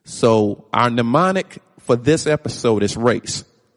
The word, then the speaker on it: mnemonic: And the word mnemonic, for those of you that may not be familiar with it, is spelled capital M-N as in Nancy, E-M-O-N-I-C. The M is silent when you pronounce it, mnemonic. M-N-E-M-O-N-I-C.